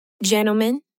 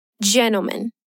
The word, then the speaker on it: gentlemen: Good evening, gentlemen. Watch out for the pronunciation here. The stressed syllable is the first one. Say it again. Gentlemen.